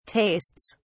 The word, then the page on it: tastes: British English